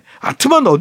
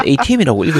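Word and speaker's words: ATM: 아, 그리고 스키장에 갔다가 돈을 좀 뽑으려고 안내하시는 분께 ATM기 어디 있냐고 물어봐야 되는데 아트만 어디 있어요? 라고 했었네요. 라고 남겨주셨네요. – 아, 정신 감정부터 좀 받아보시도록 하시고 ATM은 ATM이라고 읽으셔야죠.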